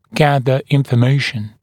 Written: gather information

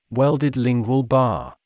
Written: welded lingual bar

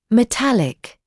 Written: metallic

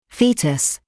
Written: foetus

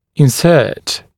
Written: insert

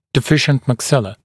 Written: deficient maxilla

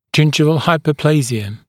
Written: gingival hyperplasia

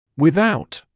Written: without

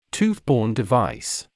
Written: tooth-borne device

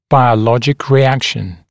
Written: biologic reaction

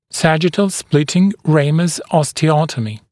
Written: sagittal splitting ramus osteotomy SSRO

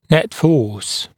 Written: net force

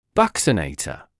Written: buccinator

